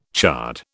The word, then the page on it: chard: British English